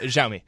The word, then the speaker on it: Xiaomi: How do you say that? – Xiaomi. – Xiaomi. Okay.